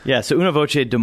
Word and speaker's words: Una Voce: But you're here today with your involvement with, it's Una Voce, right? Is that how I pronounce it? – Yeah, Una Voce, Des Moines, yeah.